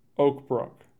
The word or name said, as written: Oak Brook